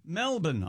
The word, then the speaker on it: Melbourne: Melbourne, Australia.